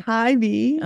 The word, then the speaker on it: Hy-Vee: Like you would have set up at Hy-Vee. Is that right? Am I pronouncing it right? – Hy-Vee.